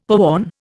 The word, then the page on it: poon: Indian